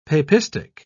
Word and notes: papistic: British English

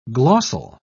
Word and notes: glossal: British English

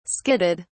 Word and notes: skidded: British English

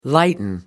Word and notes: lighten: American English

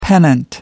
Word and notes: pennant: American English

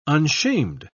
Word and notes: unshamed: British English

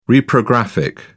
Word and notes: reprographic: British English

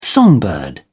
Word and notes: songbird: British English

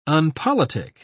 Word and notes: unpolitic: British English

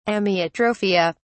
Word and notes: amyotrophia: British English

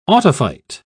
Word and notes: autophyte: British English

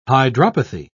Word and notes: hydropathy: British English

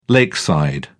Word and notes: lakeside: British English

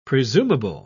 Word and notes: presumable: British English

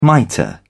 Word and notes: mitre: British English

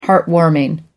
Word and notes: heartwarming: American English

